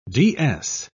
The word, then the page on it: ds: British English